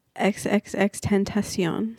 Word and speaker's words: XXXTentacion: XXXTentacion.